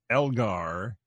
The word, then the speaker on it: Elgar: I'll try this, and I apologize if I butcher this, but I believe it's pronounced Elgar and Scheherazade at Orchestra Hall.